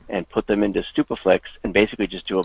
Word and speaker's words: Stupeflix: So it was Superflix? – Stupaflix. STUPEFLIX.